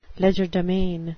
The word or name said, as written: legerdemain